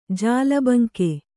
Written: ಜಾಲಬಂಕೆ

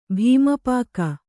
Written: ಭೀಮಪಾಕ

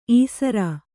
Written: ಈಸರ

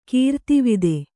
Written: ಕೀರ್ತಿವಿದೆ

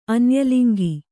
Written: ಅನ್ಯಲಿಂಗಿ

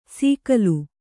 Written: ಸೀಕಲು

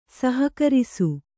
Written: ಸಹಕರಿಸು

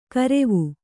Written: ಕಾರಿವಿ

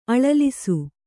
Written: ಅಳಲಿಸು

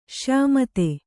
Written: ಶ್ಯಾಮತೆ

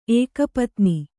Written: ಏಕಪತ್ನ

